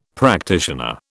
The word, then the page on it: practitioner: British English